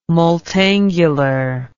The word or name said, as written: multangular